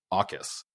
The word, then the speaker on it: AUKUS: So first, I should admit that for the last week, I have been pronouncing this new trilateral agreement between Australia, the UK, and the United States as AUKUS, which for some reason is, for me, the most natural way of reading AUKUS. But I had this sneaking suspicion that it was not, in fact, pronounced AUKUS, and so some YouTubing today before the podcast has corrected me, and it is, in fact, the much more reasonable and much more boring sounding AUKUS.